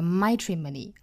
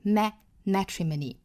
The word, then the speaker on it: matrimony: 最后这个 matrimony 第一个元音注意是梅花 a，不要读成了 matrimony 啊，应该是 matrimony matrimony，并且这个单词的尾部是一个长元音 e 的短板，也就是小写的 i，这样的发音方法是 e 而不是 a，不是 matrimony 而是 matrimony。